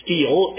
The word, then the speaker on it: stela: A portion of a stela, is I believe how that's pronounced, those often you'll hear it pronounced as steel, S-T-E-L-E, which is a standing stone slab or a pillar, recording Shishak's invasion, was found at Megiddo.